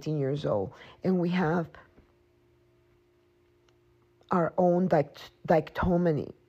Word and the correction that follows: dichotomy: dichtomony, am I pronouncing it right? our own voice, our own willpower dichotomy.